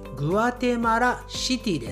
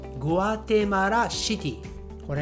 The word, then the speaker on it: グアテマラシティ: はいそしてこのグアテマラの首都キャピタルはこちらです Guatemala City はいまた同じですよね先ほどのメキシコシティと同じでグアテマラシティですグアテマラシティ